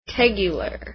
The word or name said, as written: tegular